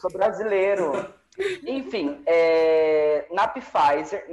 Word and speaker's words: Pfizer: Como é que fala? – Pfizer. Na Pfizer. – Ah, não é Pfizer? O P é mudo. É, por que colocaram pé então, se ele é mudo?